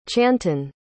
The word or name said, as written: chanton